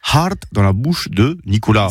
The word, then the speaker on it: hart: Hard, alors ça s'écrit H-A-R-T avec un H aspiré.